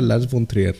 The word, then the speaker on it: Lars von Trier: Eh, para quien no conozcan, eh, Lars von Trier, se, pronuncia Lars von Trier.